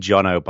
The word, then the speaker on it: Jono: No, and actually, before we even get into that, I like how you pronounce my name correctly the first time, which basically never happens in the US. I was named Jono by a kid who lived opposite a new house that my parents moved into when I was seven, and it just stuck.